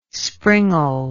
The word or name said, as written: springald